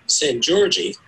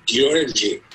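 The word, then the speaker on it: Szent-Györgyi: And I'm sure I'm not pronouncing it correctly, it's S-Z-E-N-T-G-Y-O-R-G-Y-I. And okay. I think the Hungarians say Georgi or something like that. – Right, yeah. – So for the rest of us, it's St. George.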